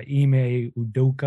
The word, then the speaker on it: Ime Udoka: And then wild card one for the Celtics, I'm going to butcher his name, but Ime Udoka. Wow. I think that's how you pronounce it. – That was pretty good.